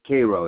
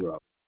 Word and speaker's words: Cairo: Cairo, like in Cairo, but Cairo is pronounced, this town, Cairo.